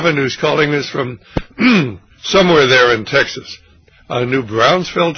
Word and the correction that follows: New Braunfels: Texas. Is that right? – Good pronunciation. That's New Braunfels.